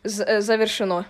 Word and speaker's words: Завершено: Завершено. – Okay. Can you try to pronounce that? – Завершено. – Did you get it correct? – Yeah, except the E in the middle.